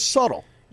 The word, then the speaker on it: subtle: Well, the word is subtle. – Yeah. – Why do we spell it subtle? – I have no idea. – I know why. – Do you? – Because the B is silent.